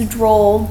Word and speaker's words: Droll: And I think my name is actually Courtney Droll. I think I actually used my real. Can you spell your name? Because Courtney's the name that's spelled differently. – C-O-U-R-T-N-E-Y, and then it's Droll, D-R-O-L-L.